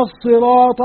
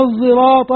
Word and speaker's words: الصراط: القراءة الحلوة طبعا القراءة الثلاثة كلها حلوة، هي قرآن ربنا نزلها، لكن الثالثة قراءة خلف العاشر قرأها بين السين والزاي كما قال ابن جزي، بين السين والزاي ينطق بها، يعني بين السين والزاي اهدنا الصراط قراءة خلف وهو أحد العشرة قراءته صحيحة، اهدنا الصراط المستقيم صراط الذين أنعمت عليهم قراءة خلف العاشر البغدادي، اهدنا الصراط المستقيم صراط الذين أنعمت عليهم.